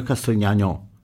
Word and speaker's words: Castrignano: E volevo proporvi, visto che sono un po' malinconico, ho scelto questa bellissima musica di Antonio Castrignano, con l'accento sulla O, che si intitola Pizzica Malinconica.